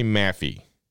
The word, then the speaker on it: Maffei: And now, he has said, and I can't pronounce his last name correctly. His name is Greg, and I want to say Maffey, M-A-F-F-E-I, or Maffei.